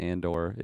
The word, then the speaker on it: Andor: Ander is the way he pronounces the OR. He said another word today, too. It wasn't Andor. It was something else with the OR, and he just did the classic Skarsgård.